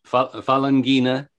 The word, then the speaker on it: Falanghina: So the Falanghia, and I'm probably mispronouncing that grape. I apologize to everyone who's listening. – That's Falanghina is the name.